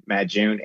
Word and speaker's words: Madyun: A-T-I-B as a boy, A, and Madyun, M-A-D-Y-U-N.